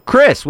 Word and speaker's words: Chris: Chris with a C, not an F.